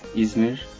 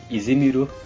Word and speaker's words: Izmir: my hometown is Izmir, Izmir. in Turkey. The, Japanese people call it Izimiru.